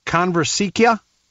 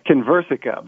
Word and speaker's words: Conversica: Our next guest is with, and I hope I'm saying this right, Conversica. Is that right? – It's Conversica, but very close. – Conversica. Well, it wasn't even close.